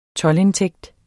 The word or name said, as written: toldindtægt